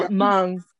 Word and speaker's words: Hmong: So we got Hmong. – And no, Hmong. Hmong. – Hmong. The H is silent.